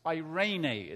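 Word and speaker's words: eirene (εἰρήνη): It's eirene is how you pronounce that.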